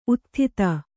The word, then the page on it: ಉದಾತ್ತತೆ: udāttate